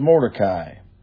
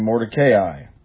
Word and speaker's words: Mordecai: If you have phonetic markings, you might call him Mordecai, which is both are okay. You know, you're not smarter, more qualified if you happen to use one pronunciation than another.